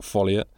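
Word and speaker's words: ffolliott: So Huntley Haverstock goes to Europe, stumbles straight into an assassination plot, teams up with George Sanders, who plays Scott Folliot, who is a genius creation. His surname is spelt FFOLLIAT, but it's with two small Fs, and you find out one. Just silent F.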